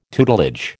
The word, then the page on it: tutelage: American English